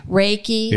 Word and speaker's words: Reiki: Is it Reiki? – Reiki.